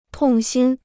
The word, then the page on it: 痛心: tòng xīn